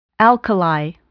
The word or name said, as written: alkali